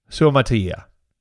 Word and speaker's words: Suamataia: Suamataia. Suamataia.